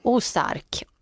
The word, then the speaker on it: Ozark: Hur uttalar man den? Ozark.